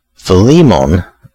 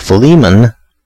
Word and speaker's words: Philemon: So that's my little update for, I guess I'm gonna try to say what I was hearing the most was Philemon, or Philemon, but then now I've heard a bunch of other people say Philemon. So that seems to be the more popular pronunciation from what I could find.